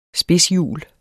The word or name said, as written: spidshjul